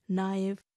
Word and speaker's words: knife: English language has a unique feature of silent letters such as the words in knife, psychology. K is always silent in knife. While writing the word, we write K-N-I-F-E.